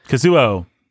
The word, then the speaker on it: Kazuo: How do you say it? – Kazuo Ishiguro. – There you go. It's kind of like it's spelled. K-A-C-U-O.